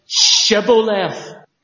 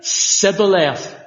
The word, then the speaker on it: shibboleth: And when a person comes along, get them to say shibboleth. So soldier come along, say shibboleth, shibboleth, friend, pass. Say shibboleth, sibboleth. Thousands of them were killed. They couldn't pronounce it.